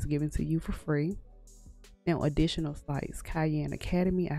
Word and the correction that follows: Khan Academy: I said like Cayenne Academy, but it's Khan Academy.